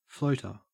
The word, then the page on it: floater: Australian English